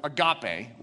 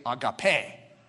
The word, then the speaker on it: agape: Love, and the Greek pronunciation, which is, I always grew up with agape, was the way you'd say it, but it's technically agape. Agape.